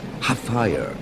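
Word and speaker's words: Hfuhruhurr: Mucha gente lo pronuncia mal. Se pronuncia tal como se escribe. H-F-U-R, R-U-H, R. Half-fire.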